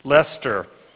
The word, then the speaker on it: Leicester: Leicester in England.